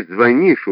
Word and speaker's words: звонишь: В слове «звонишь» ударение не на первом, а на последнем слоге.